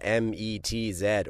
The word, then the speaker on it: METZ: Okay, so the band's name is spelled capital METZ or Z, depending on where you are. Do you say it like the baseball team or do you say it like the French town? – Baseball team.